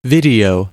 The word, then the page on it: video: [ˈvɪ.di.ˌoʊ]